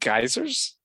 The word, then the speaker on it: guizers: You said geysers? – Geysers. It's spelled G-U-I-Z-E-R-S, and it is kind of a nickname for people who wear disguises.